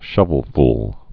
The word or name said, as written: shovelful's